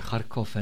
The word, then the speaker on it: Kharkov: In Kharkov en in Dnepropetrovsk.